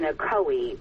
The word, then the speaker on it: Ocoee: Jennifer worked in Ocoee, which is how that's pronounced, Dana, which is literally a few miles from the scene here.